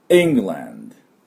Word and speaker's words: England: England というふうに発音します。で、当然ですけど最初にアクセントがありますから、イですね。これを強く。で、 land のアはあまり強く言わなくて、 land ぐらいでいいんです。 land じゃないです。 England、 England。こっちのイの方に力を入れてください。